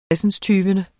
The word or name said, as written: tresindstyvende